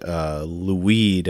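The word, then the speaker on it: Lhuyd: Davidson quotes a Welsh naturalist named Lwyd, I think is how you say his name. It's spelled L-H-W-Y-D.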